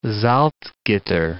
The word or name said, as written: Salzgitter